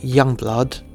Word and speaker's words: Yungblud: Dominic Richard Harrison, born 5th of August 1997, known professionally as Yungblud, pronounced Youngblood. I say young, it's my dialect, it's young blood.